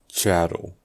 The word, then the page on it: chattel: American English